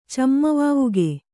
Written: ಚಮ್ಮವಾವುಗೆ

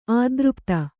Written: ಅತೃಪ್ತ

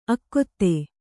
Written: ಆಗತೆ

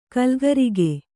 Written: ಕಲಿಕಾರಕ

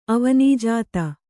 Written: ಅವನೀಜಾತ

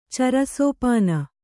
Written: ಚರಸೋಪಾನ

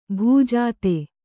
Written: ಭೂಜಾತ

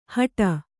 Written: ಹಟ

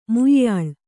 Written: ಮುಯ್ಯಾಳ್

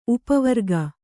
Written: ಉಪವರ್ಗ

